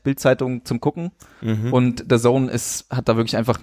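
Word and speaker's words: DAZN: Ach, so spricht man das aus. – Ja. Du meinst Datsen? Datsen. – Das heißt The Zone.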